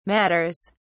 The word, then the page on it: matters: British English